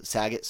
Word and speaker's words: Saget: But for those that may not have heard, comedian Bob Saget did die over – Saget, sorry if I mispronounced. – You did. – Put too much of a C in there. I think I got, like, saliva caught back.